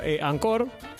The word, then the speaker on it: Anchor: también lo pueden escuchar por Ancor que se, eh, Néstor me enseñó que se dice Ancor, pero se escribe Anchor.